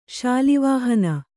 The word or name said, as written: ಶಾಲಿವಾಹನ